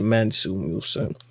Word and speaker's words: Mansa Musa: M-A-N-S-U-M-U-S-A. I'm pretty sure it's Mansa Musa.